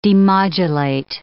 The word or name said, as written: demodulate